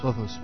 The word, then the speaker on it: Lothos: Lothos.